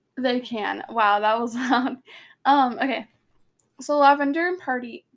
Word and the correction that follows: Parvati: I really butchered that name, did I not? Lavender and Parvati both giggled and looked at Harry, which, like, I think Harry, that would make me really uncomfortable.